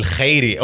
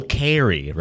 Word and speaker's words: Khairy: My guy, Basil Khairy, or as they say, Basil Khairy, right? – Yes, sir. You got to pronounce it with the K so they think you're a white dude.